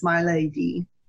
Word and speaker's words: Maleady: My name is spelled M-A-L-E-A-D-Y, and it's my lady. It's pronounced.